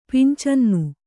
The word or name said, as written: ಪಂಚಾನನ